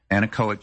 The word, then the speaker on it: anechoic: This is the, how do you pronounce it, anechoic? – Anechoic chamber. – The anechoic chamber.